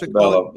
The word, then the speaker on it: Montebello: And is it called Montebello? Is that how you pronounce Montebello. – It? – Montebello. Yep. Montebello.